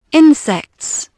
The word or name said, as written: insects